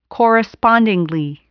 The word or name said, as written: correspondingly